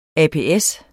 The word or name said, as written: ApS